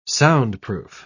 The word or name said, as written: soundproof